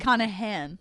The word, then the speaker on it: Connachan: Is it a hard C? – The second C is silent, actually. – Connachan? – Yeah.